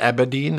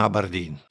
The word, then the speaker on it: Aberdeen: Also Aberdeen, sagt er endlich, Gott sei Dank am Ende, und nicht Aberdeen. Das heißt nicht Aberdeen, das heißt Aberdeen. Ganz okay. You're the guy who farted in Aberdeen.